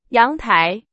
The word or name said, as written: 阳台